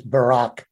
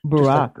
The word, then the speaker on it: Barraque: And so I'm really curious, what inspired you to write Down in the Baroque? – That is a long story, actually. So I can keep it short. And the pronunciation is Barack. – Barack. – Barack Obama, but I came up with it in 1979.